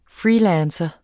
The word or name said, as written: FREELANCERE